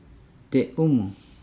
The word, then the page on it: de umu: Nukuoro